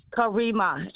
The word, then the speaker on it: Karima: Karima, with an M.